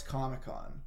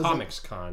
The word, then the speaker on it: Comic-Con: And then Miles says, what's Comic-Con? And then his mom says something about Comic-Con in this, and Miles... – Comics-Con. – Like, Comics-Con. Yeah. And don't say it right.